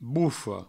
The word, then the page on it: bofa: boufo